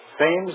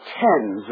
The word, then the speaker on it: Thames: I shall probably end it all by leaping into the Thames River. – Oh, Mr. March, it's pronounced Thames River.